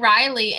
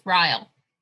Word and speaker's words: Ryle: Like I said it Riley and you're like, I think it's Ryle or... Yes. – Yeah, like Kyle with an R, Ryle. – Yes. Oh my. Yes, that's how it's spelled. R-Y-L-E.